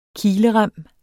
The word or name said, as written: KILEREMME